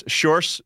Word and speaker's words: Sjors: My name is Sjors, uh, which roughly translates to George in English, which might which might be a bit easier for you to pronounce.